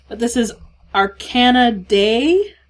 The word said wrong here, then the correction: Arcanadea: I'm not sure if I'm pronouncing that right. It's Arcanadea. And that one, actually, she's from Ottawa.